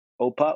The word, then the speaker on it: OPA: Yeah, you can go by OPA, O-P-A.